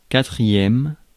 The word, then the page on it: quatrième: /ka.tʁi.jɛm/